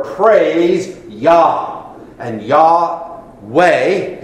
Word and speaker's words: Yah: And we learn the way to pronounce God's personal name, if you please, when we look at the Hebrew word hallelujah, last part, Yah.